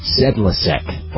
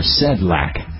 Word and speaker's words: Sedlacek: Originally from the Bellevue News Democrat, Terry Sedlacek, and I've heard it pronounced in different ways, Sedlacek, or Sedlak, S-E-D-L-A-C-E-K,